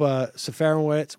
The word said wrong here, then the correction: Serafinowicz: Can you pronounce his name for me, please? – Peter Serafinowicz. – Serafinowicz, right.